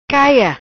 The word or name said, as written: Caia